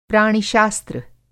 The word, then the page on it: प्राणि-शास्त्र: Hindi